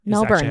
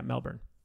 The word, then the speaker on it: Melbourne: Melbourne, how you say it. Yeah, Melbourne.